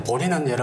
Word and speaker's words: 보리: 예, 근데 보리는 여러분들 아시듯이 보디, B-O-D-H-I 해가지고 산스크리트인데 한문으로 한글로는 보리라고 읽어요.